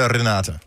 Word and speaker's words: Renata: Når du er sammen med din familie, siger de så Renata? – Ja, det gør de. – Og man ruller lidt på æret, ikke? – Renata.